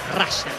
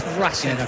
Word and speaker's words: Russell: No, pronunciación la real es Russell. – Russell. – Sí. Muchos le dicen Russell. Está perfecto, pero la pronunciación correcta es Russell.